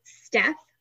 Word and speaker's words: Stef: I am on Instagram and Facebook as Wellness with Steph. My name is spelled S-T-E-F.